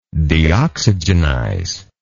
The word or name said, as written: deoxygenization